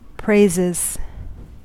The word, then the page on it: praises: American English